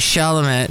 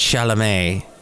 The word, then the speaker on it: Chalamet: Director Paul King, who also did Paddington, and Timothy Chalamet, who is in the... Or is it Chalamet? Shall we do the French pronunciation?